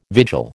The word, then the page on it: vigil: American English